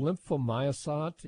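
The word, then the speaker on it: Lymphomyosot: It was made in Germany, but it's called lymphomyosat. It's spelled L-Y-M-P-H, like lymph, and then M-Y-O-S-O-T-T.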